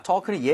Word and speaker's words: talk: 자, talk는 얘기하다고 당연히 L 발음 들어가요, 안 들어가요? – 안 들어가요. – talk라고 하시면 절대 안 됩니다. 아시죠? 입 모양을 조금 벌리는 걸 추천을 드릴게요. 그래서 talk보다는 talk. 약간 벌려서 talk.